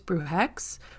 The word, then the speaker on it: bruje: and specifically with the term brujo or bruja, they use the term in English, it'd be, I think it's brujex, but in Spanish, as far as I know, and I've discussed with a number of people, including my partner, the way you would pronounce that would be bruje.